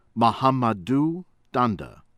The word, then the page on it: Mahamadou Dandah: American English